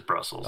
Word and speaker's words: Brussels: It is Brussels.